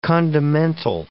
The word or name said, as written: condimental